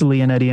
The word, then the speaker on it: Lionetti: It's spelt Lionetti, L I O N E T T I, but uh, it's Leonetti, in fact.